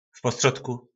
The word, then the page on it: v postrodku: po našimu